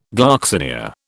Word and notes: gloxinia: British English

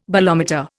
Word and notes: bolometer: Indian

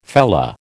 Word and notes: fellah: American English

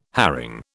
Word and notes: herring: British English

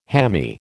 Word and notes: hammy: American English

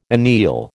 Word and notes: anneal: American English

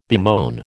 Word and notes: bemoan: American English

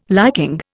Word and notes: liking: Indian

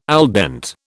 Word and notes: al dente: British English

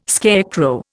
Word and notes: scarecrow: Indian

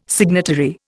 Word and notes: signatory: Indian

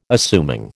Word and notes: assuming: American English

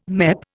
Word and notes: MEP: Indian

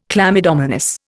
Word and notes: chlamydomonas: Indian

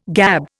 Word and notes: gab: Indian

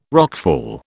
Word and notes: rockfall: British English

